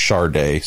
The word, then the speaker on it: Sade: And just as a sidebar, his first name, Jade, which I love, I pronounce Jardé, like the singer, whose name, if you read it on the page, could be Sadie or Sade, but she pronounces it, or it is pronounced Jardé.